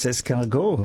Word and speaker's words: escargot: I think it's escargot. Is that how you say it? – Yes, escargot, of course.